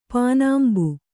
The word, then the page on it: ಪಾನಾಂಬು: pānāmbu